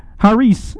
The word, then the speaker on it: charis: Now, the word for grace is the Greek word charis, and you don't hear the C there. That's the way they pronounce it in the Greek language.